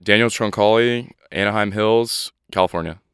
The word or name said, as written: Daniel Troncale